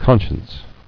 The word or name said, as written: Conscienceless